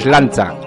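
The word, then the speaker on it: Sláinte: Si quieres brindar como un auténtico irlandés, cuando choques tu copa, tienes que pronunciar la siguiente palabra gaélica. Suena lancha y significa salud.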